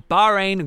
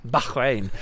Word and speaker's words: Bahrain: the 2017 Bahrain Grand Prix, although I do feel I should say Bahrain because that's actually how it should be pronounced, so I'm going to try and do that from here on in because I'd like a pronunciation, as people know.